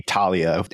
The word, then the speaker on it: Thalia: Talia feels right to me, but Talia feels right. She's named after a muse too, so however that's pronounced. – Mm-hmm. It would be Talia. Ask me later, but there's a whole thing about the way stop consonants work in ancient Greek, but sorry.